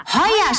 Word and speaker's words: Heuer: Ach ja, Heuer schreibt man mit OI.